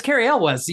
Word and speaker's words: Cary Elwes: Acting is what I remember being bad, especially Cary Elwes. – Yes, however you say his name. – And it's Cary Elwes.